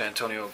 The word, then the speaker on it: Antonio: Officer Antonio Graham, ID badge 2965.